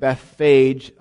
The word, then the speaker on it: Bethphage: The town Jesus was passing through, we pronounce it Bethphage often in English. In Hebrew, it would be Bet Phage.